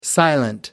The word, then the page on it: silent: American English